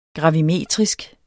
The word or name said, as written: gravimeter